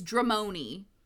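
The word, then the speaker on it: Dramione: But I kept calling the like ship name wrong. It's Dramoni, in case, like, I knew that.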